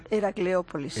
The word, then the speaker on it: Heracleópolis: Desde 1984 dirige la excavación de Heracópolis, lo he dicho bien. – Heracleópolis. – Heracleópolis, Jesús.